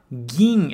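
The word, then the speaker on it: gin (銀): Então, só toma bastante cuidado na hora de pronunciar e de escrever, principalmente se for em hiragana, ok? Então, gin é prata, kin é ouro. Se for escrever em hiragana, gin é o ki com dakuten,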